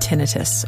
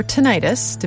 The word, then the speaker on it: tinnitus: The documentary was about a man who had tinnitus, or tinnitus, depending on how you pronounce it.